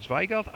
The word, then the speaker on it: Zweigelt: The other red grape is called Zweigel, which is an Austrian red grape. – How you spell that? – Z-W-E-I-G-E-L-T.